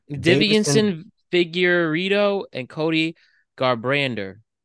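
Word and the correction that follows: Deiveson: Like, it's spelled really weird, but it's literally just Davison. It's spelled weird, but it said Davison.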